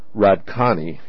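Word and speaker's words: Hradčany: The Golden Lane at Radkani Castle in Prague, and I'm probably butchering the pronunciation, H-R-A-D-C-A-N-Y, Castle in Prague, had traditionally been home to the king's alchemists, and one of their tasks was developing firearms and potent gunpowder.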